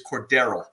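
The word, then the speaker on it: Cordarrelle: And I believe the official pronunciation of Mr. Patterson is Cordero. So they kept saying Cordero, Cordero. So I think they got it right.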